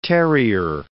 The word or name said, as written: terrier